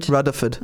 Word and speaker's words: Rutherford: You know, you've got to say it right. – Rutherford. Oh, okay. – It's a D, not a TH.